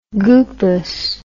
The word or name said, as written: goopus